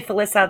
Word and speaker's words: Felisa: Hi, it's actually Felisa.